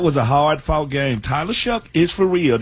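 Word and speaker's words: Shough: Like, they are a good, I don't know how he gets that pronunciation out of the spelling, but he is a very good quarterback right now. – So what's the correct pronunciation? – I mean, it shook. It looks like show to me, right? Where did you get the k sound? It's an O-U-G-H.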